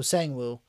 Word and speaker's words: Sang-woo: This is when we see Ji-hun's friend, So-hoo, right? So-hoo, if I'm not mistaken. Right? That's his name? – Sang-woo? – I thought it was Sun something. – Sang-woo? – Is that his name? Spell it. – S-A-N-G. – Okay, so Sang-woo.